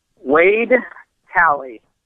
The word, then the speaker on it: Wade Talley: Wade Talley. – And Talley is T-A-L-L-E-Y.